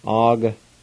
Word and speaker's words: agatadegi: A-G-L-G-A. A-G-L-G-A. I'm thirsty. A-G-A-T-A-D-E-G-I. And the A with the G again is silent, so you just say it like this. A-G-T-A-D-E-G-I. A-G-T-A-D-E-G-I.